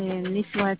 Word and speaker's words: Niswate: Oui, le nom c'est Niswate.